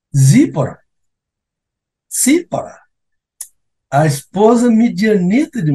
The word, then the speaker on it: Tsipora: para alguns comentaristas, seria seria uma segunda esposa, mas na verdade é a própria Zípora, esse lindo nome, que se pronuncia como TS. Cípora, Cípora, T-S-I-P-O-R-A.